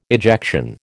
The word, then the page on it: ejection: American English